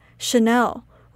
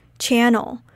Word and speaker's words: Chanel: Chanel is pronounced like Chanel with a sh sound rather than a ch sound. Back in the days, I used to pronounce it like channel.